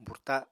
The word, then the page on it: burta: Apatani